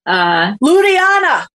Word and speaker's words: Ludhiana: Ludhiana.